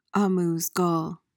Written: amuse-gueule

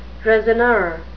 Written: raisonneur

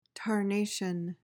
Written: tarnation

